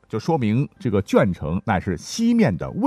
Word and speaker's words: 鄄城: 那跟江苏紧挨着呢是山东啊，也是有很多这样的地名了，像鄄城。说实话，我一直以前读增城啊，才知道还有临渠，会经常被念作邻居。